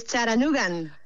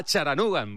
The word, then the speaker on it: Chattanooga: Es Charanugan. – Ah, Charanugan, vale. ¿Y el nombre de la ciudad cómo se pronuncia bien? – Se dice Charanuga, Charanuga, con la T como muy suave, sí.